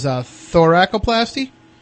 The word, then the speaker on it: thoracoplasty: And then one that I found that said that they used as a last resort was thoracoplasty. Is that correct? Am I pronouncing that correct? – Yeah, I believe you pronounced it right.